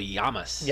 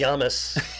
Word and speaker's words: Lamas: He's reading about Lorenzo Lamas on Falcon Crest. Again, Lorenzo Lamas was also in a show called Renegade. – Anything... It's pronounced Lorenzo Llamas. – Llamas.